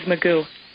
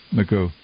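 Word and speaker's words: McGeogh: Is the mother of her daughter was killed in Iraq, Army Specialist Holly, McGyo? Help me out, please. Good morning to you. – Good morning. – How do you pronounce the late her name? – It was Magoo. – Magoo, okay.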